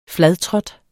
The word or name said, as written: fladtrådt